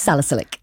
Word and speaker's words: salicylic: acid. Is that how I pronounce it? – Salicylic. – Thank you.